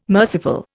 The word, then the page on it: merciful: Indian